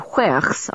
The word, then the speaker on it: huerch: "Because witches and huerchs are forbidden to marry," Kit said promptly. – I'd never heard the word huerch before, with its whistling W at the beginning and guttural sound at the end.